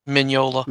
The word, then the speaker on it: Mignola: Mignola. How you say it? – Mignola.